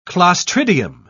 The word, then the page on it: clostridium: British English